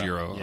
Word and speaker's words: gyro: It was the gyro. Or guy. How do you pronounce that? Like, everybody says it differently. It is gyro.